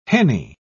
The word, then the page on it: henny: British English